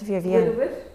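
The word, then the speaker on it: vırvır: And it's Wir Wir, but it's not, it's pronounced Wir Wir. Yes, in Turkish. And she wrote it out for me. So V-I-R-V-I-R as one word.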